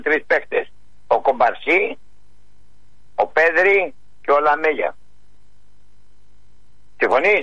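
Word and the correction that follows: Yamal: Ο Γιαμάλ λες.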